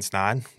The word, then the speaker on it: Snaadt: Today, I have Ryan Snaadt with Snaadt Media Group, right? – Yes, yeah. Ryan Snaadt. It rhymes with odd. That's what I always tell people.